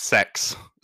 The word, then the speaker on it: CEX: called CEX, or it's pronounced sex sometimes.